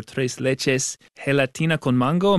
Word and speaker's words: tres leches gelatina con mango: She felt that she could do the recipe for tres leches gelatina con mango.